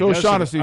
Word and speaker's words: O'Shaughnessy: So, how do you say his name actually? – That's as Irish as it sounds to me. Sure, O'Shaughnessy. – It's O'Shaughnessy. – I believe it is.